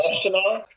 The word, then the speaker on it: Washtenaw: Washtenaw.